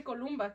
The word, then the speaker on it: Columba: I'm just like, because I don't know how people say my name wrong, but like Mexicans, I'm like, you can say Columba, because my name is supposed to be like that.